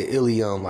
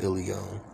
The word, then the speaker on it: Aeileon: So the last five letters are E-L-E-O-N. Ilion. Ilion. But I say Ilion like I-L-L-E-O-N. Ilion. I don't spell it that way. I spell it A-E-I-L-E-O-N. Because I didn't like ill being associated. But I do pronounce it Ilion.